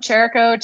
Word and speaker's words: Chirico: And then they just started calling me Cheerio. So like the cereal. Yep. Chirico, Cheerio.